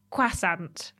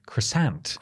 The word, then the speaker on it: croissant: Or as the Americans would pronounce it, croissant. – Croissant. – Croissant. – Yes. – You got to put the emphasis on the second part of the word.